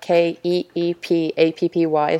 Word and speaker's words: Keep Appy: So it's spelled like K E E P A P P Y. So it's kind of like Keep Happy, but Keep Appy.